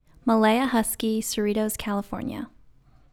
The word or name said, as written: Malaia Huskey